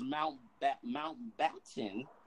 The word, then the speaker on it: Mountbatten: Now, if I pronounced it wrong, I apologize because Mountbatten.